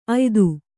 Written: ಅಯ್ದು

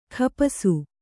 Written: ಖಪಾಸು

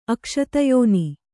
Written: ಅಕ್ಷತಯೋನಿ

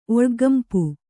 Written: ಒಳ್ಗ್ಗಂಪು

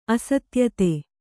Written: ಅಸಾಧ್ಯತೆ

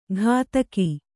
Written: ಗದಕಿ

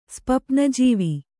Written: ಸ್ಪಪ್ನಜೀವಿ

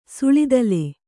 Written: ಸುಳಿದಲೆ

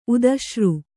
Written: ಉದಶ್ರು

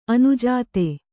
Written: ಅನುಜಾತ

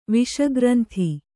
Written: ವಿಷಗ್ರಂಥಿ

